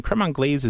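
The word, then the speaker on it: crème anglaise: And one more recipe you were going to share with us, macerated berries with, I never know how to say it, creme anglaise.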